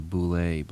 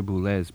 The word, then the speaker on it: Boulez: In the old days we used to say Boulez, but Pierre Boulez pronounces it Boulez for some strange reason.